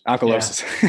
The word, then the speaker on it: alkalosis: The study describes it as what they think is going on is that, I always say this wrong. Alkalosis. – Alkalosis. – Yeah.